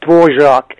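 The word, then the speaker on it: Dvořák: I don't know how the Americans would pronounce that. We say Dvorak in Czechoslovakian.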